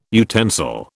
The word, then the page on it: utensil: British English